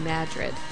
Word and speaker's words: Madrid: The Madrid in New Mexico is actually pronounced Madrid.